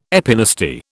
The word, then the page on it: epinasty: British English